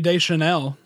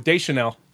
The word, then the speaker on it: Deschanel: Zooey Deschanel. I don't know anything about this. – First, hit the mulligan button. Next, we got Zooey Deschanel. – Oh, Deschanel.